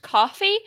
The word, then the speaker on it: Ko-fi: I think it's actually supposed to be pronounced coffee, but it does look like Ko-fi.